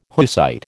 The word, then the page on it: Hussite: American English